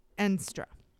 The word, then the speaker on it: Endstra: Another Willem, Enstra. And this is spelled E-N-D-S-T-R-A. So I'm going to say Enstra, but that's how it's spelled.